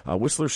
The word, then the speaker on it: Wisler: I believe it's pronounced Whistler, W-I-S-L-E-R, Whistler Street in Stockton, New Jersey.